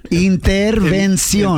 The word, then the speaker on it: intervención: Yeah, it's the N. Just get the N, people. Intervención.